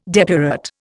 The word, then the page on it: depurate: Indian